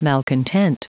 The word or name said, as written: malcontent